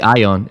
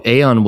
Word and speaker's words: Aeon: A lot of people say Ion. is actually Aeon. Yeah, you're right. Nice, nice. Tell us about Aeon. What's the deal with Aeon? Oh, Aeon was uh, is one of those painful processes as we just described.